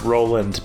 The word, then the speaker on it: Rowland: It's actually pronounced Roland, but for some reason, sometimes people like break their neck trying to pronounce it like Rowland.